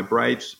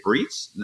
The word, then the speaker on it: Breitz: And so B-R-E-I-T-Z doesn't mean anything. – It stands for Brights. – Exactly. So it's pronounced that way. You know, some people say Breets. And so when I write it, I say pronounce Brights.